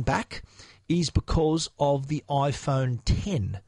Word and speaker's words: iPhone X: Now it's when you look at the name of this phone, it says iPhone X, but X being the Roman numeral for 10, Apple were pronouncing it 10 on the stage. I keep catching myself calling it X, calling it 10. So it is the 10 is Apple's official name for it.